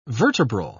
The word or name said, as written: vertebral